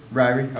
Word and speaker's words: Ryrie: And, you know, I like the way a guy named Charles Ryrie, I believe that's the way you pronounce his name. I'm not sure. I'll ask Bob, because he got me pretty good a few weeks ago about a mispronunciation. Ryrie works? – Ryrie. – Okay.